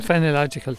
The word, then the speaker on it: phenological: Fien, how do you pronounce it? – Phenological.